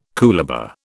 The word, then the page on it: coolabah: British English